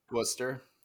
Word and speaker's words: Worcester: Worcester.